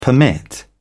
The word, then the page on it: permit: British English